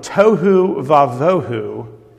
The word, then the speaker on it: Tohu Vavohu: And if you want to know how to pronounce that little title there at the top of your bulletin, it's from Tohu Vavohu to Tov.